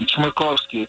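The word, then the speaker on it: Chmerkovskiy: Your full name is Maxim Chernovsky, right? – Nope, you misspelled it. You misspoke it. – There's a lot of wrong there, but, you know, a lot of wrong there. – It took like 15 years to perfect the name, and but Chmerkovsky, it's a tough one.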